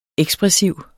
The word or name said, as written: ekspressiv